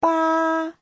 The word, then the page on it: 八: ba1